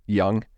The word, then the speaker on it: Jung: It's George, J-U-N-G, but I think it's pronounced Young.